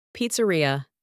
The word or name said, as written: pizzeria